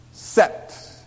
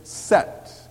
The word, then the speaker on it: Seth: Adam lay with his wife again, and she gave birth to a son and named him Set, which would be the modern Hebrew pronunciation of the word, named him Set.